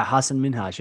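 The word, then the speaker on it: Hasan Minhaj: Did I say his name right? – Yeah, Hassan Minhaj.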